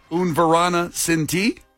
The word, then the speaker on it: Un Verano Sin Ti: Also, Bad Bunny's Un Verano Sin Ti. Kenzie, am I saying that right? It's 4 words there. Un Verano Sin Ti? – Yeah, Un Verano, a summer without you.